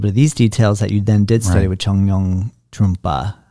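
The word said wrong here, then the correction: Chögyam Trungpa: Chogyam Trungpa. – Sorry, I was mispronouncing it.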